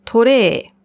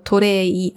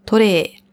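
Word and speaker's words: Tore (トレー): Dann das Tablett, Tore oder Torei. Also man kann es mit I schreiben oder auch eine Verlängerung, Strich statt dem I. Tore. Die Aussprache ist trotzdem das gleiche. Tore.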